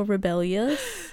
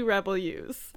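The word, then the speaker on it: Rebellyous: There's a Y in there, so I don't know if it's Rebel Use or Rebellious. – It's definitely Rebel Use.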